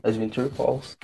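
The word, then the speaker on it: Adventure Pals: Eu não sei a pronúncia correta, P-A-L-S. Adventure Pulse. Acertei, Adventure Pulse.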